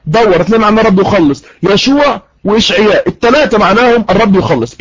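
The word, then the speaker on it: يشوع (ישוע): النقطه رقم اثنين، النقطة رقم اثنين، قلنا ان يسوع في العبرية تنطق يشوع.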